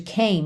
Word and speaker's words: came: Porque a letra M de came não é a última letra da palavra, certo? A gente tem C-A-M-E. Só que o E não é pronunciado.